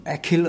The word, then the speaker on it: Achill: Ja, eigentlich äh, spricht man also, also Achill natürlich, äh, wenn es klassisch wäre, aber im Englischen spricht man es eben Achill aus und natürlich ist es irreführend weil äh, ich glaube, dass auch sehr viele Iren nicht wissen, woher dieser Name kommt.